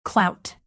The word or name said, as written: clout